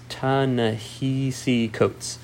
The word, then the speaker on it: Ta-Nehisi Coates: I want to make sure I pronounce it right, it's Ta-Nehisi Coates.